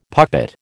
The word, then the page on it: puppet: American English